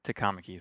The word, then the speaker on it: Takamaki: I thought they called her by her last name, which is like Takamaki.